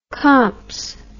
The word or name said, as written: comps